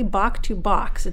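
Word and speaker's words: Bach to Bock: So did I pronounce this correctly? Bach to Bach. It's B-A-C-H and then B-O-C-K. – Bach to Bach, yeah.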